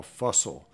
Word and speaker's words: Fussell: There's one by a man named Paul Fussell. It's spelled like Russell and pronounced that way.